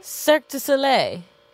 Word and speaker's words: Cirque du Soleil: He's in Cirque du Soleil. Is that how you say it? Cirque du Soleil? – But isn't it Cirque du Soleil? – Cirque du Soleil. That's how you're supposed to say yeah.